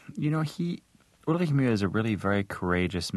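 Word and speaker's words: Ulrich Mühe: I mean, the sound, which is in his last name, doesn't actually exist in the English language. So it's actually Ulrich Muhe.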